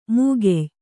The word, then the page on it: ಮೂಗೆಯ್: Kannada